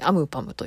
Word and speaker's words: AMPM: アーティスト名がアムパム AMPM と書いてアムパムと読みますそして、